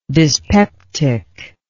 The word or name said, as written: dyspeptically